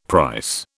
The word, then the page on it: price: British English